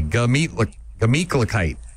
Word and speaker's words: Gemütlichkeit: I'm going to get you to pronounce it properly. Here we go. Gah. – Gah. – Meat. – Meat. – Cluh. – Cluh. – Kite. – Kite. Glah-meat. – Not a glah. Gah. Just G-A. Gemütlichkeit.